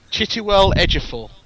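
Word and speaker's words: Chiwetel Ejiofor: I'd like you to pronounce the third name on the list. – Chitiwell Ejiofor. – Oh, yeah. Well done.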